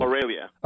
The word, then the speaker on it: Aurelia: I always have trouble pronouncing it. Ariella? Am I Ariella? – Aurelia. – Aurelia. – Yes.